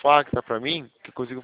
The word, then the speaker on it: Facta: Facta. Vou ver aqui, tá. Vou ver aqui. – Pera aí, eu vou até entrar no sistema. Ah, o Facta ele é FACTA. O Tá. – C é mudo.